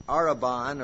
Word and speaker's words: arrhabon: This is the Greek word arabon, or it's spelled A-R-R-H-A-B-O-N.